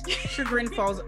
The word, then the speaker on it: Chagrin Falls: It actually takes place in Chiron Falls, Ohio. I think it's how you pronounce it. Chiron Falls. Chagrin Falls, Ohio, which is outside of Cleveland, which now makes sense now that I know that Abby's from Cleveland.